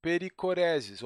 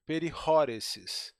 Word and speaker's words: perichoresis: e pericoreses, ou melhor, pericoreses. Mas, se você quiser uma pronúncia grega ainda mais precisa, perihoreses.